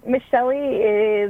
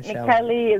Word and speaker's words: Michieli: So Michieli is, but Michieli is the Italian pronunciation. So either or, I'm good with it.